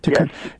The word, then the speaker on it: Tikkun: There was an article that appeared in Tikkun. Am I saying it right? I keep wondering if I'm... – Yes. Tikkun.